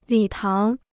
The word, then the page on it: 礼堂: lǐ táng